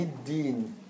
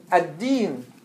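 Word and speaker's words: Ad-Din (الدين): Malik Yawm Ad-Din. Ad-Din. Ad-Din. Is it Shamsiyya or Qamariyya? – Shamsiyya. – Shamsiyya. Do we say Al-Din? We say Ad-Din.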